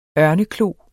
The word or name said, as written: ørneklo